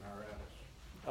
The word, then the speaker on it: Aratus: And the poets are Konethes and Aratus.